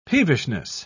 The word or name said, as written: peevishness